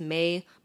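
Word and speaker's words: Mei: It's M-E-I. It's May, but it's pronounced like the month.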